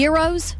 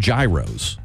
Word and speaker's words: gyros: Yeah, now that's how I pronounce it. But before, I called them gyros. I didn't know. – Okay. Gyro. – Or gyro. But they're gyros. Yeah. That's how they pronounce them.